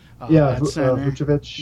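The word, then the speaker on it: Vučević: For the Bulls, DeMar DeRozan's on this team, Derek Jones Jr. You know, they've got a nice – Vukicic, I could never say his name. – Vucevic.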